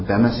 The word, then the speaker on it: Bema: The Bema seat is really how it's pronounced.